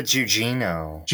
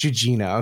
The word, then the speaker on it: Gugino: It stars Carla How do I pronounce her name? – Carla Gugino. – Gugino, okay. It stars Carla Gugino as Karen Sisco,